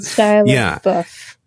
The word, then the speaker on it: Shia LaBeouf: But what's most interesting is this is the first movie I've ever seen with Shia LaBeouf. – Yeah. – Is that how you pronounce it? – Shia LaBeouf.